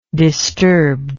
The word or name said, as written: disturbed